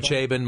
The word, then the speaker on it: Chabon: No, Chabon. Is that how you pronounce his name? – Chabon? Oh, Chabon, Michael Chabon.